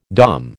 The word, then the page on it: Dom: American English